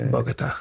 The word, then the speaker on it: Bogota: and you come back from that to Bogota. – Yeah. – Am I pronouncing it right? Yeah. Bogota.